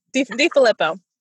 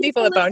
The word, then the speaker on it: DiFillippo: Close. DiFillippo. Kim DiFillippo. Nobody gets it right.